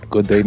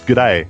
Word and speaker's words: g'day: First of all, you're saying it wrong. No, you can't say good day, mate. It's not two words. It's one word. It's a contraction. It's g'day. All right. You only say the g.